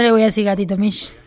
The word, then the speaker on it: Misch: Tom Misk. Es difícil de pronunciar, aunque es muy simple su apellido. Es M-I-S-C-H.